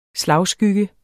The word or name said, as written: slagskygge